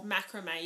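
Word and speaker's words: macramé: Is that how you say it? – Macrame? Yeah, macrame. – Yeah, macrame. – Some people say, like, macrame or... – Macrame, yeah. – Yeah, but it's macrame.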